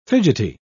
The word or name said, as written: fidgety